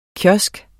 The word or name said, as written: kiosk